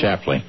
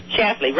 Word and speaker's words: Schlafly: Schlafly. – Schlafly, right.